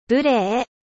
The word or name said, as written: impoli